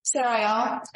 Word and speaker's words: Sariel: Sarayel, S-A-R-I-E-L, and Sandoval is S-A-N-D-O-V-A-L.